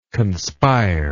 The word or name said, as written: conspiringly